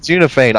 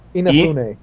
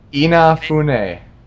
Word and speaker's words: Inafune: I thought it was Unifane. I have heard it pronounced Unifane on the Game Makers thing. – How do you pronounce it? – Inafune. – Inafune.